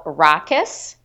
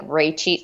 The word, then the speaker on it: Rakkes: Okay, so it took me a minute to figure out how to pronounce this, but it's called Rakkes. It looks like Rachie, like you would pronounce it Rachie's, but it's called Rakkes, and it's a Sauvignon Blanc.